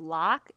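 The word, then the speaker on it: Loq: And they're by the brand, I think it's pronounced Lock. It's L-O-Q.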